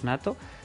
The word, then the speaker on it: NATO: Que viene a ser la OTAN, pero como lo pronuncian ellos, Nato.